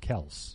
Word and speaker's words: Kelce: We're mispronouncing his last name. – Travis Kelce. – Apparently, his dad said, "Yeah, no, it's pronounced Kelce."